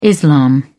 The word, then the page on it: islam: British English